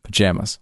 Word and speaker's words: pajamas: How do you pronounce the second syllable of P-A-J-A-M-A-S? – Pajamas. – Pajamas. – Yeah. – With a vowel in jam. – Yeah. – I would go with the vowel in palm.